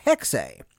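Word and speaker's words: Hexe: It is pronounced Hexay.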